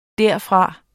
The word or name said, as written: derfra